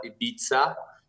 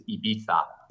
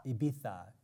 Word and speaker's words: Ibiza: So, uh, you know, many people will call it Ibiza, uh, but the actual way to pronounce it here in Spain is Ibiza. – Ibiza.